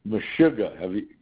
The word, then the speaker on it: meshuggah: And it's pronounced. Meshuggah.